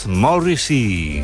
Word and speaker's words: Morrissey: Por eso ha creado este número musical llamado Morrissey, o como se pronuncia en inglés, Morrissey.